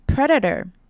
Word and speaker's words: Predator: 再来看到 Predator, Predator 这个字呢比较特别，可能比较少见，首先要注意到是它的重音在最前面 Predator. 然后还要注意到的是 E 的发音呢是 A 的音 Pred, Pred. 然后呢 A 的发音呢是 A 的音 Predator Predator.